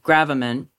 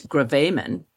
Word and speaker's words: gravamen: After last week's episode where I used, and maybe you did too, the term gravamen, I received an email correcting me that the actual pronunciation is gravamen.